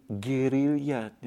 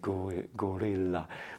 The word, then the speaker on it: guerrilla: Number two, the guerrilla strategy, and then I'm using the Spanish pronunciation of the word, and not to say gorilla, but it's not gorilla.